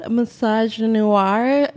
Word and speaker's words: misogynoir: It's called misogynoir, like misogyny and noir put together.